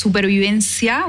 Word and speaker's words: supervivencia: yes, supervivencia with the C in Spanish is how you say it before survival.